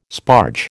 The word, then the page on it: sparge: American English